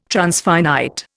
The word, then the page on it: transfinite: Indian